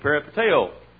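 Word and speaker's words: peripateo (περιπατέω): The word walk in the Bible is the word in the Greek peripateo. P-E-R-I-P-A-T-E-O is about the way we pronounce it.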